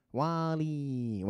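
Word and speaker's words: WALL-E: Wally. Ouais, c'est comme ça. C'est comme ça qu'il faut le prononcer normalement.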